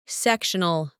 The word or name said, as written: sectional